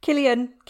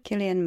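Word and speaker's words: Cillian: With Killian. Do you say it like that? – Killian. – Killian Murphy.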